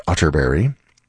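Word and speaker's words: Atterberg: It's spelled Otterberg, but pronounced Otterberry. I have it on good authority.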